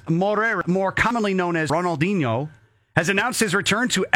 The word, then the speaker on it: Ronaldinho: Ronaldinho.